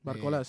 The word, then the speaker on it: Barcola: Es un gran entrenador y por ejemplo, pues tenemos el caso de Barcola, ¿no? Si no lo he pronunciado bien. – Barcola, sí.